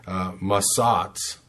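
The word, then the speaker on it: Matsats (מצץ): comes from this other word that is basically pronounced very similar, Matsats, and that is M-A-T-S-A-T-S.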